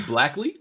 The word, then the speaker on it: Blackley: I think you said it right the first time. – Oh, you mean the murder box? – Is Blackley? – I think so. – Seamus. – I thought it was Blackie.